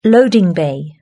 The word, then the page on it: loading bay: British English